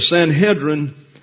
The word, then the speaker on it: Sanhedrin: The Sanhedrin, when you see the word in the Greek, is Sunedrian.